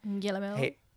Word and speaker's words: Guillermo: How do you say his name? – Guillermo.